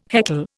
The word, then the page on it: heckle: Indian